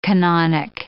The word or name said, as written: canonic